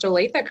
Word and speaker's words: Olathe: I'm very impressed that you pronounced Olathe correctly.